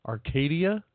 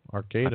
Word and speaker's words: Arcata: Wendy from, do they pronounce it Arcata or Arcata? – Arcadia? – No, it's spelled A-R-C-A-D-A. – Oh, Arcata.